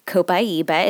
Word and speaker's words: Copaiba: Copaiba is how you pronounce it, and it is even more awesome to use.